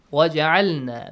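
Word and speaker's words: وَاجْعَلْنَا: So, وَجْعَلْنَا. Actually, it is إِجْعَلْنَا. But when you read it with waw, Hamzatul Wasl will be skipped and Jim will be having a bouncing sound.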